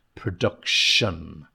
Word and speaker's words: production: Initially, wealthy, get the TH sound, wealthy, mass production, that S sound, mass production, shun, production, that T-I-O-N, on the end of the word, shun, production. Key, K-E-Y, key, like the car, key, the door key.